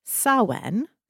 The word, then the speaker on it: Samhain: So, for all Wiccans, Samhain is held on October 31st. – Well done. I'm really pleased that you pronounced that. – Thank you. – Because it's actually, it's not spelled how it sounds.